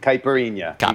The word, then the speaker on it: caipirinha: Yeah, a lot and caipirinha. – And caipirinha. You got right on the money.